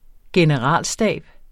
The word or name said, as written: generalstab